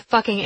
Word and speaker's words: fucking: "You pronounce the entire word, F-U-C-K-I-N-G. – "How should I pronounce it?" – "Fucking. Leave off the hard G."